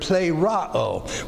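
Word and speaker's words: Plero (πληρόω): By the way, the word for fulfilled here is the word plero. We've talked about it many times. Looks like this. P-L-E-R-O-O. Double O. A-O sound. This is long O. This is short O. P-L-E, long E, pronounced A. Plero.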